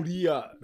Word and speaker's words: Syria: How do you pronounce Syria the right way? – Syria. – Isn't it Surya or something like that? – Surya.